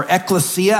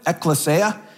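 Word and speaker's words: ekklesia (ἐκκλησία): And so this term ecclesiological, if we break it down a little bit, we can see that it arises out of the Greek term ecclesia, or ekklesia, I should say, is a right pronunciation of it, ekklesia.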